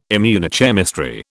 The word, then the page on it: immunochemistry: British English